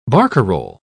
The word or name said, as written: barcarole